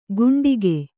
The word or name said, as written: ಕುಂಡಿಗೆ